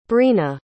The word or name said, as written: brina